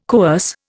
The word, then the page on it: coerce: Indian